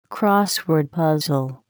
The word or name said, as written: crossword puzzle